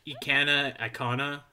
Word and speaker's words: Ikana: I feel like Ikana.